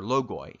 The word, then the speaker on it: logoi: They the logi are uncreated operations which become the archetypes for creatures are called logi, or logoi, if you want to use Erasmian pronunciation.